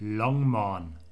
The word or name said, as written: Longmorn